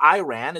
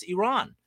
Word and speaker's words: Iran: It's not Iran, it's Iran.